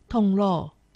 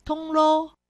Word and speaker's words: 铜锣: 客家话四线腔发音“ 铜锣”，海陆腔发音“ 铜锣”。